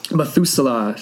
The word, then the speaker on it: Methuselah: Methuselah.